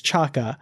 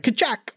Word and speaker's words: K'Chak: Now, apparently the name of this particular character on Titan is, the pronunciation is Chaka. It's spelled K'CHAK'! So in my head, when I see it, I'm like, Kachak, op.